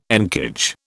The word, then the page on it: encage: British English